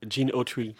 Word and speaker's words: Gene Autry: Gene Autry.